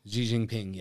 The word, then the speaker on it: Xi Jinping: Xi Jinping? – Xi Jinping, yes.